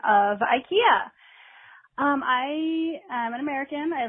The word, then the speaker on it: IKEA: And I just wanted to let you know that the proper pronunciation of IKEA in Scandinavia is IKEA. The I sound is like an E sound here, so it's IKEA.